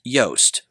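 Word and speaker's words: Joost: I've recently come to understand, I believe, I'll probably get it wrong again, but his name sounds like toast, so it's Yost.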